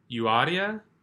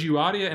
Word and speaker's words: Euodia: I urge Euodia, and I urge Syntyche," that is how you say it, I looked it up, "I urge Euodia and I urge Syntyche to live in harmony in the Lord.